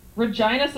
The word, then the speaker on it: Regina: Regina, Saskatchewan. – Yep, the city that rhymes with fun.